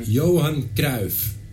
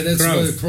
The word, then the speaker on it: Cruyff: Johan Cruyff. – Thank you, because it, you can't. – Cruyff. – You just can't. – Cruyff. Yeah, that's Cruyff.